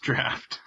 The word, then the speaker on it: draught: it is actually pronounced draft.